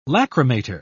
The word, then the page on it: lacrimator: British English